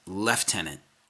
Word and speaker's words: lieutenant: Quote, "Please God, stop pronouncing lieutenant the way it's spelled and the way Americans pronounce it," which I don't criticize. "It's lieutenant in the British and Commonwealth armies," unquote.